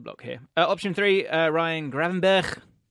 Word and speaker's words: Gravenberch: Okay, say it properly. – Gravenberch. – Gravenberch. – Gravenberch. – Yeah. Gravenberch. Gravenberch. He's perfect. Gravenberch.